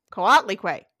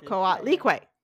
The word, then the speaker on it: Coatlicue: Coatlicue. Nope. Coatlicue. I have the syllable L-E-E in all caps so that I know that that's where what gets the emphasis. Coatlicue.